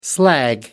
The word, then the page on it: slag: American English